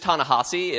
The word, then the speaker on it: Ta-Nehisi: It is Ta-Nehisi. It looks like the H-I is actually pronounced Ha, like H-A-H.